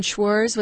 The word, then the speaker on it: Schwarz: I just found out that the conductor of the Seattle Symphony, his name is the same as mine, Schwarz without the T, S-C-H-W-A-R-Z, Schwarz. It rhymes with s'mores, remember, in Cub Scouts, Girl Scouts.